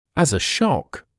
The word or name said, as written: as a shock